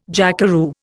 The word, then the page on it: jackeroo: Indian